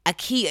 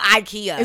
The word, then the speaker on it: IKEA: How to pronounce IKEA? It's like IKEA. It's not IKEA.